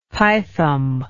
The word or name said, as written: pithom